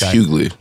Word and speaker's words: Hughley: I just assume that it's Hughley, like Hugh Laurie, a white guy. It's Hughley. – Hughley, see? – Did you ever watch the Hughleys growing up? – I did, yeah. I guess I just never heard it pronounced correctly.